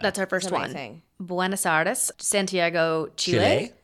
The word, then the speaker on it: Buenos Aires: Buenos Aires.